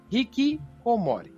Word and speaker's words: hikikomori: Eles são aqueles que os japoneses chamam de hikikomori. É assim que se pronuncia, Nando? Exatamente, solda.